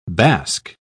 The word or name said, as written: bask